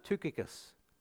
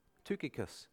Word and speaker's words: Tychicus: His name was, again, apparently it's pronounced Tychicus. Tychicus. Tychicus.